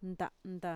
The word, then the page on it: nta'nta: Mixtec